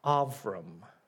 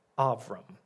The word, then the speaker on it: Avram: His name, if you're not familiar with it, in Hebrew is pronounced Avram. Avram.